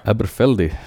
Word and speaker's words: Aberfeldy: Eller hur var det man uttalar nu professorn? Aberfeldy. Aberfeldy, okej. Okay.